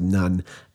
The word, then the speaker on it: Nun: His name is pronounced Nun, N-U-N.